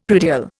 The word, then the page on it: predial: Indian